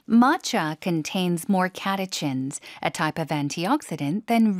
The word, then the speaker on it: catechins: Matcha contains more catechins, a type of antioxidant than regular green tea. – Catechins라고 읽습니다. 영어에서는요. 발음 다시 한 번요. – Catechins. – 앞에 힘 주고 catechins.